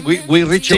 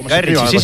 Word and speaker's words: Guy Ritchie: ¿Lo he dicho bien? Guy Ritchie. – Sí, Guy Ritchie, sí.